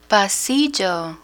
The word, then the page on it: pasillo: Spanish